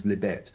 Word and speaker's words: Libet: And then one day I was reading a copy of New Scientist, and there was an article in there about a man called Benjamin Libet, although some people pronounce him as Libet,